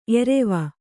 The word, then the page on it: ಏರುವೆ: Kannada